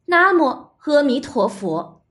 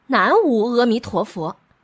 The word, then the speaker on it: 南无阿弥陀佛: 因此，南无阿弥陀佛正确的读音应该是。 – 南无阿弥陀佛，不能念成南无阿弥陀佛。在这里呀，给大家特别提醒一下，千万不要念错了，会让别人笑话的。